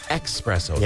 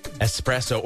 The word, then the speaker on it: espresso: It is not pronounced espresso. – Yeah. – There's no X. There's no express caffeine drink at your local coffee establishment. It's pronounced espresso, or some people pronounce it espresso, which I did not realize.